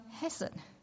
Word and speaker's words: hesed: He calls him, he is my hesed, my steadfast love, verse 2. My steadfast love. If you use the Hebrew word, it's only two syllables, hesed.